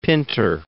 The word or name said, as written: Pinter